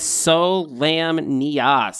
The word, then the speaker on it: Solamnia: They actually have a pronunciation guy here, and it is Solamnia, so you're correct.